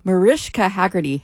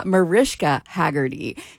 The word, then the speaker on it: Mariska Hargitay: I just said in the last break, Mariska Hargitay hates when people mispronounce her name. And there, I just did. I got it right the last time. Mariska Hargitay.